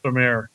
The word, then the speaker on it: Stormare: Stormare.